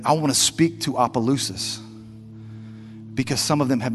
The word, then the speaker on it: Opelousas: Opelousas. Opelousas.